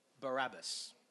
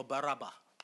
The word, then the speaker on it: Barabbas: His name was Barabbas, or Baraba, however you want to pronounce it.